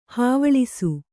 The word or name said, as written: ಹವ್ವಳಿಸು